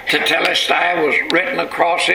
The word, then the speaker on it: tetelestai: And in this sixth last saying of Jesus, when he cried out, "It is finished," in the original language of the Bible, there's one Greek word, tetelestai. I guess that's the way you pronounce it. T-E-T-E-L-E-S-T-A-I.